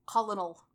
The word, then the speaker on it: colonel: Also, why is Colonel spelled like that? – I blame the French. – Colonel.